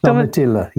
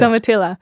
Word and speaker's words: Domitilla: Am I saying that right? Domitilla. – yes. Domitilla.